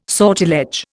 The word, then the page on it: sortilege: Indian